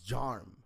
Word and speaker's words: Jarm: Well, it's Jarm Carlos Ramirez. – John Carlos. – No, Jarm Carlos. – Jarm. – Yeah, Jarm. – Yeah. Like, spell that. – J-A-R-M, as in Mary.